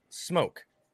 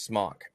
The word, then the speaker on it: SMOK: Like my wife vapes, and she has a mod, and on the mod, it's spelled S-M-O-K. – Okay. – I say that's pronounced smoke. But there's other people that say, you're saying it wrong. It's pronounced smock. I'm like, no, even the website will tell you it's pronounced smoke as if it had the E there.